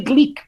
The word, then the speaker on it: Glück: Custa-me um bocadinho dizer glico, porque eu percebo, mas eu acho que, é, que, é, que, é, que é o, que, é, que é a pronúncia norte-americana, não é? Ou inglesa, não é norte-americana, mas pronto, passada para, para, para, os Estados Unidos a ler o glico.